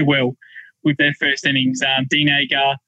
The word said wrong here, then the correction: Elgar: uh, Elgar, sorry.